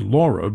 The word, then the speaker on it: Leah: Now, if we have another big storm coming up, and I'm reading this part, we're going to have Josephine, Kyle, Leah, which is pronounced Laura, but it's spelled L-E-A-H, Marco, not for the Secretary of State, Nana, Omar, Paulette, Renee, Sally, Teddy, Vicky, Wilfred.